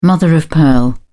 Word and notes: mother-of-pearl: British English

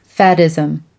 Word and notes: faddism: American English

